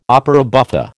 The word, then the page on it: opera buffa: American English